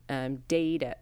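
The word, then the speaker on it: Daeida: The actual name of Hollywood came from Daida. It's D-A-E-I-D-A. I specifically looked up the pronunciation.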